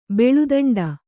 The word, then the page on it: ಬಾಳೆದಿಂಡು: bāḷe diṇḍu; Kannada